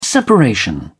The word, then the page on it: separation: British English